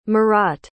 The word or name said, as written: marotte